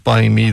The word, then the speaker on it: Buymie: It's B U Y M I E, so it's Buymie is uh, what we're looking at there.